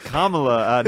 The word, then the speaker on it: Kamala: We got to hang up on I think it's pronounced Kamala, not Kamala.